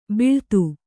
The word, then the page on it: ಬಿಳ್ತು: kannada